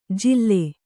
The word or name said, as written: ಜಿಲ್ಲೆ